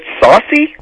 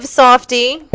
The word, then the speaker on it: Softe: It's Dave Softy, and it is the Metal Meltdown show. – Yeah, what is the last name again? Spell it. – S-O-F-T-E. – Oh, Softy, like Mr. Softy the ice cream.